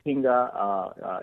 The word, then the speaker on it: Hiinga: Please say the word for me. – Yeah, that's correct. That's Hinga, H-I-I-N-G-A. And there is a, you know, redundant I there, but yeah, it's Hinga. – Hinga.